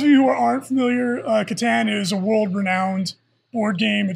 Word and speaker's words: Catan: Is it pronounced Catan or is it Satan, like you were trying to say right there? – No, it's Catan.